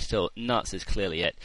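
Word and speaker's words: Knuts: I know the Scholastic site says it's Canutes, but when Jo was reading Haploid Prince in Edinburgh, she pronounced it as nuts, no K.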